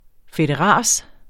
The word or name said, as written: fedteras